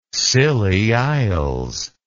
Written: Scilly Isles

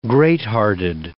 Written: greathearted

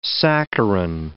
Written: saccharin

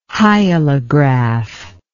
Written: hyalograph

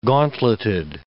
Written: gauntleted

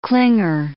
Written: clinger

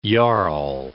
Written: jarl

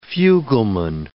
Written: fugleman

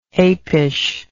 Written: apishness

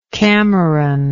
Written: cameronian

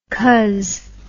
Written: cuz

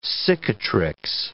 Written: cicatrix

